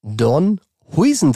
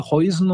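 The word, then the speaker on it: Huijsen: Dean Don Huisen dran. True or not true? – Ähm, das war true. True. Also erstmal, der heißt, glaube ich, Dean Donny Heusen, äh, aber war fast richtig.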